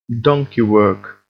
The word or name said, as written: donkey work